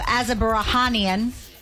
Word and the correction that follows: Azerbaijan: Baku, Azerbaijan.